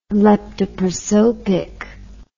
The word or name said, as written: leptoprosopic